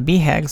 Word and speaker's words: BHAG: At Built to Last, we identified bags, again, B-H-A-G's, as a key way to stimulate progress while preserving the core. It's pronounced BHAG. A BHAG, short for Big Hairy Audacious Goal, is a huge and daunting goal, like a big mountain to climb.